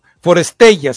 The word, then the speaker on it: Forestella: No, pero es que la recomendación no es solo la canción, es, es, la interpreta un cuarteto coreano, eh, Forestella se llama, For e e l a así como Forestella sería la, eh, pronunciación puntual.